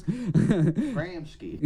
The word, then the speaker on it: Gramsci: Gramsci. – Gramsci.